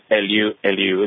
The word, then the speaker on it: Elihu: Elihu. – How are you doing, doctor? – Okay, did I pronounce it right? – Yes, Elihu. It's a Jewish name.